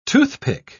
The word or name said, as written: toothpick